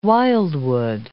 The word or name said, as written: wildwood